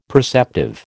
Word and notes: perceptive: American English

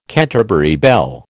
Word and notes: Canterbury bell: American English

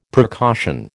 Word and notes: precaution: American English

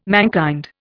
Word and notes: mankind: Indian

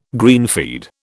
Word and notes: greenfeed: British English

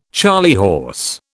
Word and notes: charley horse: British English

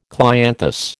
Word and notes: clianthus: American English

